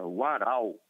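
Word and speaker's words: Warao: A pronúncia é Waraó.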